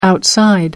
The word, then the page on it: outside: American English